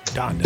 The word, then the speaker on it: Donda: Donda.